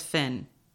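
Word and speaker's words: Fionn: In the audiobook, she says Finn. It's F-I-O-N-N. – I always pronounce it as Fion. – Yeah, me too. – There would be no O if it was Finn.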